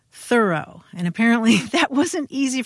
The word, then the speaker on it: Thoreau: Thoreau, with the accent silent on the first syllable.